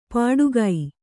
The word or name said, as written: ಪಟುಗೈ